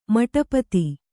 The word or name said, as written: ಮಟಪತಿ